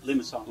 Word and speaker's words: Limassol: Limassol.